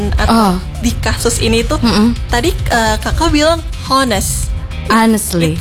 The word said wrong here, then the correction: honestly: Itu salah kak, seharusnya tidak pakai H. Jadi kita pronounce-nya honestly. – Okay, honestly. – Oh, berarti the H one is the silence. – Silence. – Yeah. H is silent.